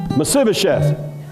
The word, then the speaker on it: Mephibosheth: It was like Meshibasheth. How do you say that? Uh, Say it. Mephibosheth. Fibba, Mephibosheth.